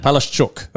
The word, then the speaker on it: Palaszczuk: Yeah, Palaszczuk. That's it. Palaszczuk. It's SHCH sound.